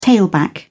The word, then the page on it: tailback: British English